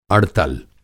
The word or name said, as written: अड़तल